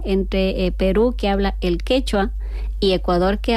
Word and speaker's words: quechua: Sí, sí, el quichua es de Ecuador y el quechua es de Perú.